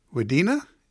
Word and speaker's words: Wadena: Let's go to Ruthie in Wadena. Did I say that right, Ruthie? – Yeah, yes, you did.